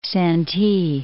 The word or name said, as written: Santee